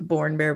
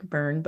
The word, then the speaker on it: Burn Bear: I always want to say it like Born Bear, but he gets mad at me because he says it's like Burn. But even though he's Irish and he says it Born, it's whatever.